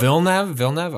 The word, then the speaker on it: Villeneuve: I think it is Villeneuve. – Villeneuve. Okay. – Yeah. But I only think that because, like, in F1, like Jacques Villeneuve was like a major F1 driver, and I always heard it said that way.